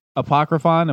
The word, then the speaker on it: Apocryphon: Warp Riders and Apocryphon.